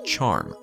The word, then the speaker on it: X-ARM: They've approved a replacement for the Hitomi, a smaller X-ray observatory called X-ARM, pronounced Charm.